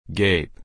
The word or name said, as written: gape